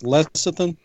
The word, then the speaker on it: lecithin: I just want to call in and let you know that the word is, it's pronounced lecithin.